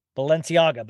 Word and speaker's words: Balenciaga: He didn't have a change of underwear or shorts, and he called uh uh Bals- how do you say it Balcianega? Balenciaga, Balenciaga.